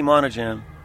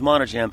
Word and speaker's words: Monadjem: Rodney Monajam. – Can you spell that? – Sure, it's last name is Monajam. M-O-N-A-D-J-E-M.